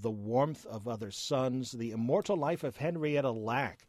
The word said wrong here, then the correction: Henrietta Lacks: was one which was brought up by a couple of people. Excuse me. Henrietta Lacks is the correct pronunciation there.